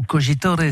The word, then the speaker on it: Cogitore: Le comédien est de retour au cinéma dans ce film signé Romain Cogitor ou Cogitore si vous prononcez à l'italienne.